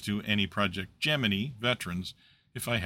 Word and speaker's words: Gemini: If you're like me, you desperately want to pronounce the name of this program as Gemini, but the NASA history is quite explicit that the project itself used the pronunciation Gemini.